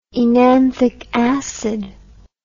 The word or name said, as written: enanthic acid